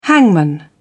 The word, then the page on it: hangman: British English